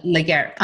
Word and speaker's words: Legere: And I'll tell you what I read about T-Mobile in, how do you pronounce the CEO's last name? John Legere. – John Legere.